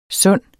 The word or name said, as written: sunde